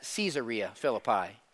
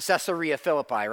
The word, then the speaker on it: Caesarea Philippi: Verse 27, he and his disciples went on to the villages around Caesarea Philippi. Everybody look here. You can also pronounce that Caesarea Philippi.